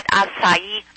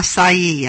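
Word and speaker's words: açaí: I think that, I don't know if you're pronouncing acai. I've heard it acai. – Yeah, acai, I think. – Acai, right.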